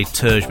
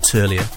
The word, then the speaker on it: Terje: And then the next track I've got is another summer hit for me, being Todd Terje. Some people say Terje, but it's actually pronounced Terje.